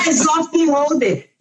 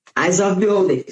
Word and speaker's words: Eyes of Beholder: Eu vou escrever como que fala. Pra vocês não. Aqui, ó. Olha aqui, ó. Eyes of Beholder. Gente, não tem erro, tá? Eyes of Beholder.